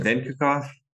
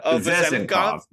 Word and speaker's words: Vezenkov: Vinkakov? – What was that? – I've never learned how to say their... Vezenkov.